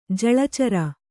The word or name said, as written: ಜಲಚರ